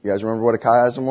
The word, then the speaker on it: chiasm: It's pronounced chiasm. That's how we say it. You guys remember what it was? C-H-I-A-S-M.